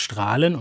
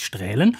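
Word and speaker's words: Straelen: Ich sage nur den Ort Strahlen, der sich mit AE schreibt, was man auch für ein Ä halten könnte, aber er heißt Strahlen und nicht Strählen.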